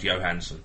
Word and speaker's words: Johansson: She's I think her dad was Swedish or something like that, and that would be pronounced Johansson.